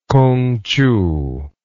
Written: kungchiu

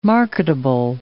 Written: marketable

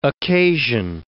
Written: occasion

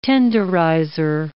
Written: tenderizer

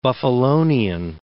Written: Buffalonian

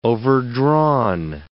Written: overdrawn

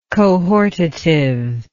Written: cohortative

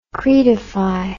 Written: cretify

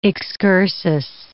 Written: excursus

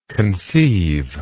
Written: conceiver

